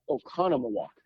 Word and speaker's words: Oconomowoc: Did I get that right? Oconomowoc? It's a mouthful. – Oconomowoc. – Fantastic. – It's spelled just like it sounds, and you say it just like it's spelled, so that works out well.